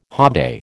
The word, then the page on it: hobday: American English